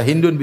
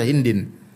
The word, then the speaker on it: Hind: Hind kalau bahasa Arab bisa Hindun, bisa Hindan, bisa Hindin. Tergantung dalam konteks apa sebagai subjek atau sebagai sebagai objek. Kalau Indonesia sudah dipaket jadi Hindun sudah.